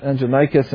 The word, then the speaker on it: Andronicus: Well, Andronicus, I pronounced it wrong, but that's the correct pronunciation, Andronicus and Junius, they were outstanding among the apostles.